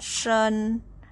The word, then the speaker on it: 身: The word 身 has sh as a consonant pronounced as 诗 and vowel is en pronounced as un with the first tone. Shi, en, shen, shen, first tone.